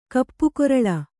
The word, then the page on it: ಕಪ್ಪುಗೊರಲ: kannada